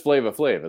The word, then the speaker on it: Flava Flav: There's no R. It's just Flava Flav, isn't it? – Right.